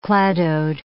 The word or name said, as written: cladode